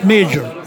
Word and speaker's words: Majar: Jarir Al Majar, pronounced major.